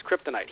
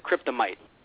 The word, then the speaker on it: kryptonite: Hell, Jerry Lawler mispronounced kryptonite. He might have said kryptomite.